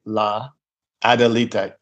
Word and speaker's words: La Adelita: La, LA, La Adelita, Adelita.